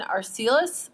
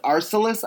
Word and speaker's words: Arcelus: Sebastian Arcelus? – Arcelus, I believe, is how you pronounce it.